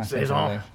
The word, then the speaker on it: Saison: Saison is French for season. – It is, yeah. Saison. – Saison. – The correct pronunciation.